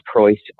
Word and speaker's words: Preuss: I am also the only Seth Preuss on LinkedIn in the world, which is kind of funny.